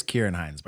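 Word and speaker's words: Ciarán Hinds: Yeah, it is Kieran Hinds, by the way. Nice job. – Okay, cool. Uh, at least that's how I'm reading the pronunciation on Wikipedia.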